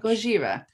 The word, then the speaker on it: Gojira: Is it Gojira? – Yeah, pretty much. – Gojira.